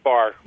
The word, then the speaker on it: Bahr: The young man who killed her, a guy named Brian Bahr, B-A-H-R, am I saying that correctly? – It's Bahr. – Bahr.